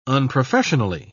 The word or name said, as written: unprofessionally